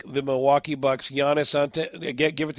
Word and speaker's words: Giannis Antetokounmpo: Giannis Antetokounmpo. – And you're leaving a syllable out, but it's a good effort. – Oh, the Greek freak. – Antetokounmpo.